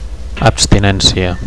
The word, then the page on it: abstinencia: Catalan